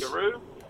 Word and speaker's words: Giru: Guru, is it? G-I-R-U? – Yes. – Guru? – Yep. – There you go. Did I pronounce that properly? – Yes, you did, mate.